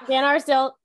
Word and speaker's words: VanArsdale: VanArsdale, that's right. It sounds the way it's spelled.